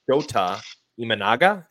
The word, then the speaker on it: Shota Imanaga: I personally think one of the most underrated free agents in this group is Shota Imanaga. Is that how you say his name? Yeah, you got it.